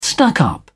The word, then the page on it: stuck up: British English